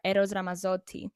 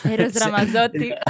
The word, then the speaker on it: Eros Ramazzotti: So I recently, last November, I had to open for the big Italian pop star, Eros Ramazzotti, in a big stadium. – You said that beautifully, by the way. People. That was really cool. – Eros Ramazzotti. Yeah, it's near the Greek pronunciation.